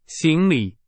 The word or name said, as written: 行李